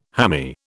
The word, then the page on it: hammy: British English